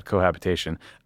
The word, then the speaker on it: cohabitation: The second bet is that if the far right wins and he winds up in a situation called cohabitation, why did I say it like that? Cohabitation,